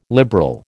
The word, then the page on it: liberal: American English